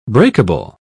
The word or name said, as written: breakable